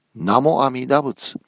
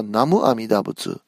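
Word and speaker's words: Namo Amida Butsu (南無阿弥陀仏): When Pure Land teachings were transmitted from China to Japan, the leaders of the Tendai sect were read as Namo Amida Butsu instead of Chinese way of pronunciation, which is Namo Amida Fo.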